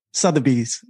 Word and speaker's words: Sotheby's: Yeah, it should be Sotheby's.